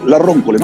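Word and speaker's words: Le Roncole: ¿Cómo se pronuncia? ¿La Roncole o la Roncole? La Roncole, ¿no? – La Roncole, sí.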